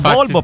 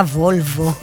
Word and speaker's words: Volvo: Ma quindi tu sei la Bolbo perché? – Sono la Bolbo per la Volvo.